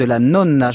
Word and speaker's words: nonna: les conseils de la nonna. Je prononce bien la nonna ? – La nonna. – Voilà, la nonna.